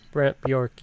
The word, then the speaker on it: Brant Bjork: That Brant, was it Brant Bjork? Is that how you say it? – Brant Bjork. – Brant Bjork, yeah. Like, he's played a lot with him and has been active with that whole desert rock scene, I guess.